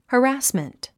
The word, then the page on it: harassment: hərǽsmənt; ハラスメント